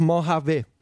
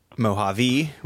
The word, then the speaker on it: Mojave: It's French. It's Mojave. – I think it's Bondi. Bondi, I think. – So I had said, Mojave with an E. That spread rather quickly, I feel like, to some other podcast that I think one even credited me for.